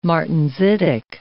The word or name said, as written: martensitic